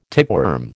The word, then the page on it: tapeworm: American English